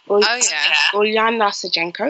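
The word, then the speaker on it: Ulyana Sergeenko: I like I can't pronounce her name. Ujena Sajenko. Ulyana Sajenko, excuse me.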